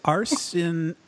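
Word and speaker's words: Arciniega: Help me with this name. Is it Arcienega? – I always just say Arcienega, but I'm not 100% on how that would be pronounced. – It's Arcieniega. So Arcieniega. Arcieniega. Arcieniega.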